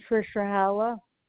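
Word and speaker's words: Friscia-Holler: It's Amy Frischerhalla.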